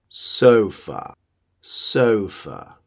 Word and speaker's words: sofa: Sofa. Como ya lo sabes, a ver si puedes hacer una pronunciación perfecta sofa, sofa. Si puedes dominar ese sonido, o, oh, o, oh, hablarás súper bien.